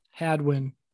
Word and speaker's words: Hadwin: Hadwin. – Just like you would think it's spelled? – H-A-D-W-I-N.